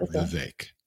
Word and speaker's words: Vivek: What he tells people is it rhymes with cake. That's how to remember Vivek.